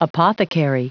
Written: apothecary

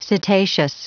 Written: cetaceous

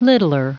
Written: littler

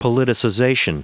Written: politicization

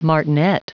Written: martinet

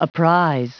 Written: apprise

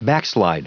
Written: backslide

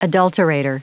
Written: adulterator

